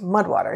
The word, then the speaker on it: MUD/WTR: That's MUD/WTR, pronounced Mudwater.